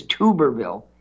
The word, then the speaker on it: Tuberville: Tommy Tuberville, I think he pronounces his name Tuberville. Everybody says Tuberville, but I think I heard him on Fox yesterday thanking, was it Steve Ducey, for pronouncing his name. He said, thank you for pronouncing my name correctly. It's Tuberville. You're the only one who's done it.